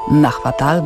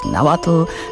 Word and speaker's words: Nahuatl: Kiedy mówicie Państwo po angielsku pomidor, czekolada, chili czy awokado, to prawie mówicie w języku Azteków, bo te słowa pochodzą właśnie z języka Nahuatl bądź Nahuatl, jakkolwiek wymawiać tę nazwę.